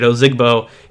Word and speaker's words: Ozigbo: Devin Ozigbo, I've been saying it wrong. So I heard in the broadcast, I'm just going to say it, Ozigbo.